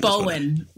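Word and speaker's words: Bohen: It was on HBO Max or something like that, and that, you know, the young Wyatt Earp was a young Ian, how you say his name? Bohm? – Ian Bowen. – Bowen.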